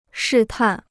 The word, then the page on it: 试探: shì tàn